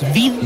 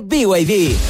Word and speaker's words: BYD: ¿Los BID? – ¿BID? ¿No es BID? – Nada que ver. Se escribe BID, pero se dice BYD.